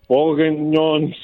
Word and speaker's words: Bourguignon: There's a slow-cooked beef cheek and bourguignon sauce.